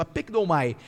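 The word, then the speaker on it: apikdomai (ἀπεκδύομαι): Essa, é, é, é, a pronúncia é essa mesmo, apikdomai.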